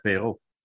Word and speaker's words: Perrault: and then probably a rotation between Paquette and Perrault? – I like the way you say Perrault. It's a very French way of saying Mathieu Perrault.